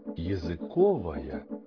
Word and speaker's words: языковая: В зависимости от значения слова меняется и ударение. Языковые колбасы, но языковые явления. Языковой процесс, языковое окружение, языковая среда.